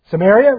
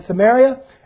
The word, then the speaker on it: Samaria: Samaria, rather. How did I say that there? I believe I said that wrong. In Jerusalem, Judea, and then Samaria.